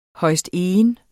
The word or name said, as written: højstegen